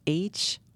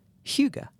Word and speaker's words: hygge: And I think it's pronounced hygge. Like I was just going to say, of course, I had to go to Google and, like, you know, type it in. How do you pronounce? And that's what they said, hygge. – Hygge. – So, yeah, I think you got it right.